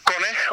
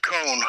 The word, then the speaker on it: kone: K-O-N-E. – Okay, right, sure. – Kone, once again, a Finnish word. – Yeah. – Kone, or as the Americans might probably pronounce it, Kone. – Yes. – But it's Kone, K-O-N-E, and it means machine.